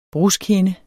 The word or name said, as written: bruskhinde